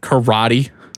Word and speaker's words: karate: Like I think like a lot of the Eastern ones, like karate. Karate is how it's supposed to be pronounced.